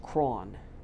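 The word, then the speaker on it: Croghan: And so there is this remarkable Irish trader, a guy named George Cron. It looks like his name is spelled Crogan, but apparently he said Cron.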